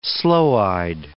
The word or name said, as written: sloe-eyed